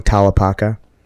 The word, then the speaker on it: Tallapaka: Shivang Tallapaka.